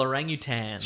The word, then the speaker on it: orangutan: Notice that I'm saying it correctly because I don't want to be killed by the noble orangutan.